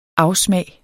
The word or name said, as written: afsmag